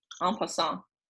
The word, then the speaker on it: en passant: E-N space P-A-S-S-A-N-T. En passant.